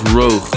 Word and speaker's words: Rook: harder up is a new track from Rook, pronounced as Roog in uh, Dutch.